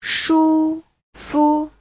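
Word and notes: shufu: shu1fu5

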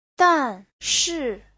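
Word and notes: danshi: dan4shi4

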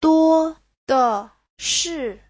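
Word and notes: duodeshi: duo1de5shi4

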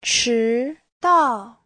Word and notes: 迟到: chi2dao4

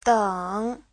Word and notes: 等: deng3

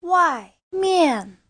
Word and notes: 外面: wai4mian4

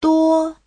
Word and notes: 多: duo1

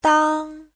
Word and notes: dang: dang1

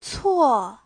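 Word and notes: 错: cuo4

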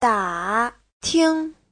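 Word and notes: dating: da3ting5

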